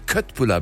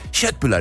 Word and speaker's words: Köttbullar: wenn ich bei Ikea stehe und die Leute Köttbullar bestellen. Das sind ja Köttbullar, ich bin Halbschwede, das ist hier, die Aussprache ist ja fürchterlich. Erling Haaland ist falsch, ne? – Ja, das ist absolut falsch.